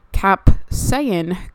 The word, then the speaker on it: capsaicin: So it is saying that you can rub this cream on your, quote, problem areas and feel the capsaicin go to work. Let me just make sure I'm pronouncing that correctly for you guys. Capsaicin, that is the correct term.